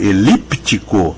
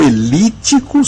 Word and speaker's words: elíptico: Então é isso, querida ouvinte, o pode ser elíptico com o P, que não é mudo, porque é pronunciado, ou elítico sem o P, e portanto não existe a letra P nessa outra opção.